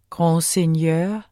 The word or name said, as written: grandseigneur